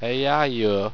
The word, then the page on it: eeihieo: Owstwecian